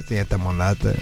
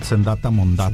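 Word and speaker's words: Zenyatta Mondatta: Senyata Mondata. – Senyata Mondata.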